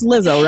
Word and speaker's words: Lizzo: Did I say that right? Is that it's Lizzo, right? – Like it is. Yes, my son loves her.